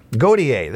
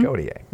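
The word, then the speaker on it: Gotye: I think I always mispronounce this. Goyet or Goit. 44 today. Had a hit some years ago. Somebody that I used to know. – Godier. – Thank you. I knew it was mm-hmm. Godier.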